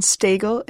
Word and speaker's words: Stagl: My name is spelled M-E-G-H-A-N, and Stagel is S-T-A-G-L. – Okay, Matthew? – On Facebook, you can find me at Matthew, M-A-T-T-H-E-W, J, Epperson, E-P-P-E-R-S-O-N.